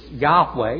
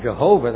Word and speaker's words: Yahweh: Well, the Hebrew is Yahweh. You may want to pronounce it Jehovah. That's not quite accurate.